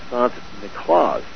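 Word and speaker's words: Sint Nicolaas: and they pronounced St. Nicholas Sant Niklaas, and we call him Santa Claus.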